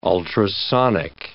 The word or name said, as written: ultrasonic